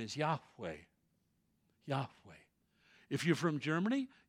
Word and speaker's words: Yahweh: So in Hebrew, they would have pronounced Jehovah Yahweh because they don't have a J or a V in their alphabet.